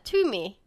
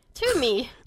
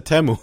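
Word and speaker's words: Teume: for Treasure's fandom name, it's pronounced what you said, Tiumi. Tiumi. – No, I said Temu. I did the opposite.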